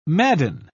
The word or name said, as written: Madden